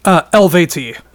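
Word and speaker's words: Eluveitie: and I mean Illuviate, is that how you say it? Illuviate? – Elvati. – Elvati.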